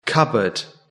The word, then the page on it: cupboard: British English